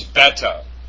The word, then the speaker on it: beta: Alpha, beta, it's not beta, it's beta.